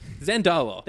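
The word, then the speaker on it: Zandalar: Zendala, I swear, is... – Say it like you mean it. – Zendala. – There you go.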